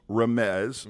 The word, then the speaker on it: Remez: Or P-E-S-H-A-T. Same thing. – Right. – Okay. The second one is Remez. Mm-hmm. R-E-M-E-Z. – Yep. – The third one is Drash. D-R-A-S-H.